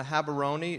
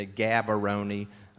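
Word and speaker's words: Gaborone: Um, you may hear some white people in South Africa say, say Gabaroni, um, or here in South Carolina, but it's Habaroni, um, sort of a silent G.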